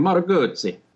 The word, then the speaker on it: Moto Guzzi: Yeah, you say it like a real Italian, Moto Guzzi.